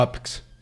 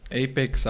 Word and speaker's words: Apex: O Apex, que no sé bien cómo pronunciarlo. – Apex, Apex. – Las dos son correctas, depende en qué parte del mundo estés.